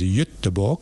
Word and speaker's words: Göteborg: in Stockholm and Gothenburg, otherwise pronounced in Swedish as Göteborg.